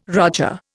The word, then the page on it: roger: Indian